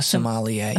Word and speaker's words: sommelier: A sommelier, yes.